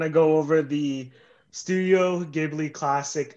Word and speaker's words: Ghibli: Right, just call it Ghibli. You said it correctly a second ago. – Ghibli. – There you go.